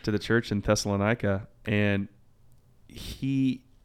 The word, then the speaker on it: Thessalonica: I think, many people call it Thessalonica. I actually discovered it's actually pronounced Thessalonica, which is kind of interesting.